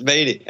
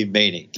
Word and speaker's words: Maney: It's Maney, M-A-N-E-Y, long E, Maney, Kevin Maney.